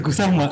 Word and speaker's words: Kusama: Kusuma. Kusuma apa? – Kusama. – Kusama dulu kalau bacanya Yayoi Kusuma sama kayak gue waktu itu.